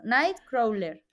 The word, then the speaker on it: Nightcrawler: Bueno, la película es Night, bueno, no sé cómo se pronuncia, pero Nightcrawler. Esta, hombre, bueno, y lo has pronunciado muy bien, de Jake Gyllenhaal, pero bueno.